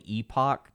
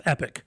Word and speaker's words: Epoch: And then I started getting ads for this this publication called the Epoch Times. – It's pronounced Epic. – Epic Times. And it looks like Epoch.